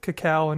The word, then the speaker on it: cacao: So, yeah, the way they describe the style of the beer is a style-defying double India Pale Ale with cacao and coffee. – Damn. – Is that how you pronounce that? Cacao? C-A-C-A-O? – Sure.